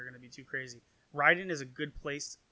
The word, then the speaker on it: Raiden: Rai, which means thunder, and Den, which means lightning in Japanese. So it's actually pronounced Raiden.